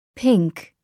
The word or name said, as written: pink